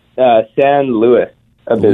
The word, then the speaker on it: San Luis Obispo: How do you pronounce that? – San Luis Obispo.